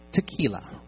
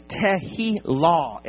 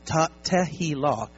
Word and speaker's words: Tehillah: Tehillah. Not tequila. Wrong spirit. Okay? There's some folks who like tequila. It's like, yes, finally. No, I'm just kidding. The actual pronunciation is Tehillah, L-A-W. Tehillah.